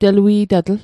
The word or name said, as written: telui'tatl